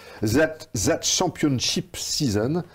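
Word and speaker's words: That Championship Season: Zat Champion... Zat Champion... Putain, mon anglais de merde Prononce-le sans... – That, Championship Season,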